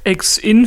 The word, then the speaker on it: EX-IN: Ex-In für die Abkürzung ist schon richtig ausgesprochen.